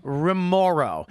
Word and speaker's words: Remauro: Remoro.